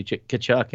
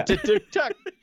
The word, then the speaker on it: Tkachuk: Look, I could get 72 points if I just gave the puck to Matthew Tkachuk and he skated it. – You have to get his name right first, fucker. – Matthew Tkachuk.